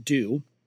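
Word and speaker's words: Doux: She has her own website, Dew Reviews, and that's D-O-U-X. But it's pronounced Dew.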